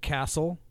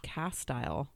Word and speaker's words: Castle: The second word is pronounced castile. – No, it's castle. – Oh. – It's Knappogue Castle is how you say it.